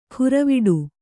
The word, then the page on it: ಖುರವಿಡು: khuraviḍu